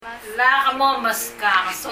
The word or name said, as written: Lak momeskak